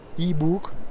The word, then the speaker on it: e-book: que es lo de un ebook. Un... Descarga este ebook, perdón, ebook, ¿cómo se dice? – E-book, acuérdese el caso de, de ¿no, señor? E-book. – E-book, ok. – Un e-book. Descarga este e-book, suscríbete a nuestra lista de correos y recibirás el e-book gratis.